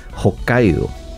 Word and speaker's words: Hokkaido: Obtuvo el título de doctor en cirugía vascular del Hospital de la Universidad de Hokkaido. No sé si estoy pronunciando bien, esto es en Japón. – La pronunciación es perfecta.